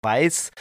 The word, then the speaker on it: Vice: Er meinte natürlich auch, Weiß wäre richtig und das Wort Weiß mit V-I-C-E im Englischen heißt auf Deutsch Schraubstock.